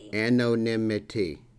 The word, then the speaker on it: anonymity: When we talk about social media recovery, I think one of the first obvious things that comes up is, how do you pronounce it? – Anonymity? – Anonymity. – Yes. – Right, one of the least understood traditions and certainly the most mispronounced tradition in all of the 12-step fellowships.